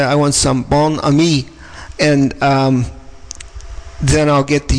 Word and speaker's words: Bon Ami: I believe it's Bon Ami, but in this country, nobody calls it that. It's Bon Ami cleanser.